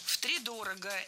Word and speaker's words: втридорого: Ну, тогда я с тебя втридорого спрошу. Вот, хотя мы знаем, в истории есть немало примеров. – Втридорого – это неверно. Правильный вариант – с ударением на первом слоге. Втридорого.